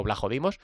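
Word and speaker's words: Vlachodimos: Bueno, es que se pronuncia así en griego, Blajodimos.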